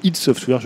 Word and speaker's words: id Software: Donc John Carmack, pour ceux qui ne le connaîtraient pas, c'est le légendaire cofondateur et programmeur d'id Software, ou ID Software, si vous voulez le prononcer comme ça. Normalement, c'est plutôt id Software, je crois.